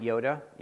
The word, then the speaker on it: iota: That's the Greek letter I, and we pronounce it Yoda.